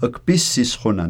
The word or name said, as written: kpisisquanan